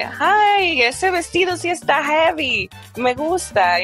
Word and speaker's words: jevi: Is the word heavy. – Heavy. – And it's not heavy as in heavy in English. – Okay. How would you spell that? – Different. Yeah. I would spell it J-E-V-I.